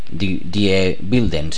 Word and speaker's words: Die Wilden: Die Wilden sería, porque en alemán es la W se pronuncia como V, lo poco que sé de alemán.